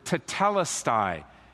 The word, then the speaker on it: tetelestai: It's the Greek word tetelestai. You want to remember that word. Let me spell it for you. T-E-T-E-L-E-S-T-A-I. Tetelestai.